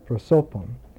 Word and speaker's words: prosopon: The Greek word prosopon, that's the emphasis, prosopon, P-R-O-S-O-P-O-N, prosopon. The emphasis in classical Greek is always on the second syllable. So you count the vowels, and the second vowel is where the emphasis is, prosopon.